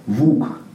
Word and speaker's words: вук: Допустим, у сербов, например, там вот ол вот не произносится, оно исчезло. То есть, допустим, полк у них звучит пук. Допустим, волк – вук.